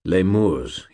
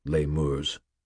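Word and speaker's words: Lemures: Lemurs, he said, with the Latin pronunciation, lemurs.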